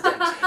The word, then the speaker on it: Dench: Isn't it Dench? – I say it wrong. That's Judi Dench. Right. – Yeah. I think it is Dench.